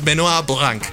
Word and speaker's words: Benoit Blanc: Parece que se cortó el cuello y todo apunta que podría ser suicidio pero nuestro querido detective es Benoit Blanc. Creo que lo dije bien en. Oui, oui. Sí, en francés, Benoit Blanc.